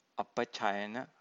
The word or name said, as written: apacayana